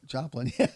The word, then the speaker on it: Joplin: Joplin.